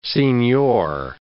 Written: signior

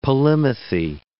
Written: polymathy

